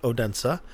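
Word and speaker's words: Odense: And I was corrected by two non-Americans on the pronunciation of Odensa, and it is in fact Odensa, and our fair Mimo, patron extraordinaire, patron prime, I think, patron patronus primus.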